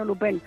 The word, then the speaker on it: Lupin: No se dice Lupin, sino Lupin.